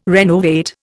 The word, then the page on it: renovate: Indian